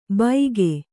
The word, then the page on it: ಬೈಗೆ: Kannada